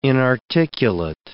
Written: inarticulate

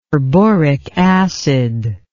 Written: perboric acid

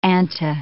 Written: anta

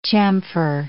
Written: chamfer

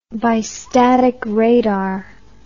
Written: bistatic radar

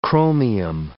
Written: chromium

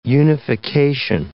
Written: unification

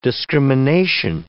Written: discrimination

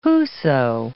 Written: whoso

